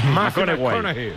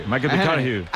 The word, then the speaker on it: McConaughey: Mateo McConaughey. – Sí. – Hay que ir corrigiendo la pronunciación. – McConaughey. – McConaughey.